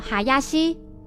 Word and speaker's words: 林: 英里认出上面的字是死者的笔迹，上面写着三个片假名哈亚西以及一个数字 2。他猜测哈亚西可能是指下次出庭要跟死者一起合作办案的那位林律师。补充一下，双木林这个字在日语的发音就念作哈亚西。